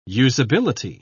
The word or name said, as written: usability